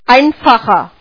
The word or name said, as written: snadný